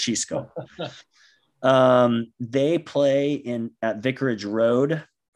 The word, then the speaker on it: Xisco: His name is spelled X-I-S-C-O. Tim, does that sound like a decent pronunciation? – Xisco? – Xisco. – Yeah, that's what I would say.